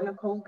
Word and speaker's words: Nichole: Okay, well, my name across my social media is Paula Nicole Good, and Nicole is spelled N-I-C-H-O-L-E, Good is G-O-O-D-E.